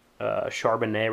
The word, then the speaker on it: Charbonnet: They got the transfer running back, Charbonnet, I think I'm saying it right. Yeah, I just looked at it. Charbonnet, right, transfer from Michigan, has been a unit this year.